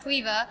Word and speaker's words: Caoimhe: but they named me Queeva, and it's actually spelled C-A-O-I-M-H-E, and so we kind of had to change the spelling because no one could pronounce my name or find me anywhere.